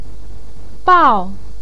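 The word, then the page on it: biao4: tone 4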